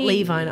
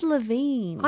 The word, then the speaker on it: Levine: Is it Levine? I don't know how to say it. – I think it's Levine. – I've always heard Levine. – Yeah, great. Maybe, but then that's the same as Avril Levine.